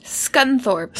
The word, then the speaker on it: Scunthorpe: but it's called the Scunthorpe problem. – Scunthorpe. – Scunthorpe. Let me spell that for you. – No. – S-C-U-N-T-H-O-R-P-E.